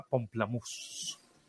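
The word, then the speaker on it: Pomplamoose: Aunque estoy viendo que se escribe diferente, se escribe Pompla Muse, como si fuera mousse de alce, pero suena justamente a pamplemousse, que pamplemousse en francés significa toronja.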